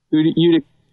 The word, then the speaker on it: Utica: Utica.